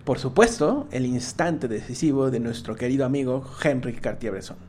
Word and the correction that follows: Henri Cartier-Bresson: Henri Cartier-Bresson, ¿no?